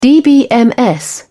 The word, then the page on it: dbms: British English